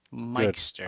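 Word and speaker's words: Myxter: Go to your dictionary and look up the word M-Y. what word is that? – Mike. – That would be the word my. How do you pronounce that? My. Now I add X-T-E-R to it. Mixter.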